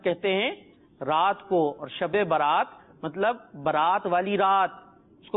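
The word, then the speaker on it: شب براءت: ہاں، تو مدنی منو شب برات نہیں ہوتا، ہم آپ کا تلفظ درست کرا دیتے ہیں، اس کا پروننسیشن درست کرا دیتے ہیں. اصل لفظ ہے شب برات.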